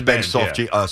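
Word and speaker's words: Benge: They say Benji, right? The kid, the outfielder. – Carson. – Oh, it is Benji. Soft G.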